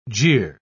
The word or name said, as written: jeer